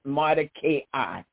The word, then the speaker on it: Mordecai: I like to say Mordecai, but most people pronounce it Mordecai,